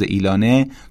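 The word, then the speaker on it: Elon: ایلون که همون تلفظ ایلانه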